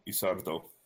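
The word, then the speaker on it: Ysidro: It's Y-S-I-D-R-O. Isardo? Sardo? – Isardo. Yeah, okay. Just so you know, anything you read in Spanish, it's pronounced phonetically. Isardo.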